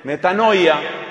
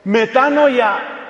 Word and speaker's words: metanoia: Ese cambio se llama metanoia, que algunos pronuncian erróneamente, metanoia.